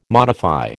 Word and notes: modify: American English

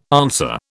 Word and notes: answer: British English

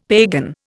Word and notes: pagan: Indian

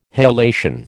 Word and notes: halation: American English

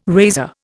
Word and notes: razor: Indian

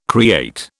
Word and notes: create: British English

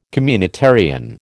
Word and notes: communitarian: American English

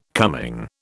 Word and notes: coming: British English

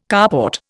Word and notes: carport: Indian

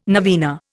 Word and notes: novena: Indian

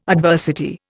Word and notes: adversity: Indian

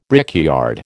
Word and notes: brickyard: American English

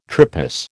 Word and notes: tripos: American English

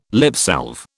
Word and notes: lipsalve: British English

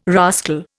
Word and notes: rascal: Indian

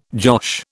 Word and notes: josh: British English

